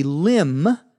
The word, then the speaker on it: limb: The phrase to go out on a limb, L-I-M-B, notice the B is not pronounced, means to take a risk, to do something that may cause you harm.